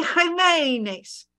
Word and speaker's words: Jimenez: J equals an H sound Remember the old Bill Dana um, comedy? My name, Jose Jimenez. So Jose Jimenez. They both start with a J.